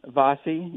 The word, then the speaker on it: Vassi: And so I'd like you to talk about how did you get interested in Marco Vassi? Am I pronouncing his last name right? – Yeah, Vassi, yeah.